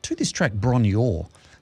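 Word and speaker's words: Bron-Yr-Aur: So Bron Yor is Welsh for Hill of Gold. Oh, it pronounces here Bronier. Oh, Bronier. There we go.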